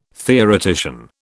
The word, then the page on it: theoretician: British English